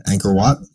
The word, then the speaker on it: Angkor Wat: Angkor Wat.